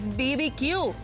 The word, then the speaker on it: BBQ: Recuerda, no se pronuncia barbecue, es BBQ.